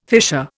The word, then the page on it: fissure: Indian